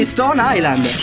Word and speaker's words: Stone Island: Questi jeans della Stone Islands. – Stone Island. – Perché scrivono come si legge? – Si chiama così, Stone Island.